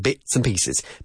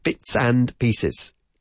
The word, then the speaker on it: bits and pieces: Bits and pieces. Bits and pieces. ¿Qué pasa? Que no solemos pronunciar la D final de la palabra and. Bits and pieces, bits and pieces.